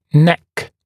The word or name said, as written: neck